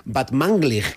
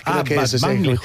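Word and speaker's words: Batmanglij: Este es Rostam Van Banglig. – Muy bien. Casi bien pronunciado, ¿eh? O sea, tercero de Radio 3 tienes casi. – Van Banglig. – Batmanglig. – Batmanglig.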